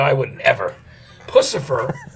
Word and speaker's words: Puscifer: It's Puscifer, because that's the right pronunciation always, right? – Puscifer. – Puscifer. Oh, yeah. It's Puscifer. Okay, I love it.